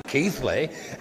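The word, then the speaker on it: Keighley: And I said, oh, you mean Keithley? And he said, it's spelt with a G on my map. As it's spelt with a G on everybody's map, but you still pronounce it Keithley.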